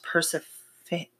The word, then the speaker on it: Persephone: Also, future reference, Persephone is pronounced Persephone. – Persephone.